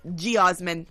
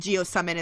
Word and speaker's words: geosmin: Some people do pronounce it geosummon as well, or geosmin. And it's basically spelled G-E-O-S-M-I-N.